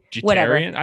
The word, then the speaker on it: Jeterian: Jeterian. I think Jeterian's pretty good. – Jeterian. Jeterian. I'm gonna emphasize the eat because you know, Jeter. Just eat.